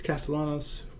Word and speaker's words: Castellanos: Castellanos. – Nick Castellanos. Castellanos, right? I mean, I wasn't sure. Some people pronounce the L's like that. Some people don't.